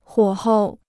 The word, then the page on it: 火候: huǒ hou